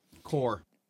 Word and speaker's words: Corps: Core. – It's C-O-R-P-S. – C-O-R-P-S is core. – What? Why? – Yeah, that's how you say it. That's how you pronounce it.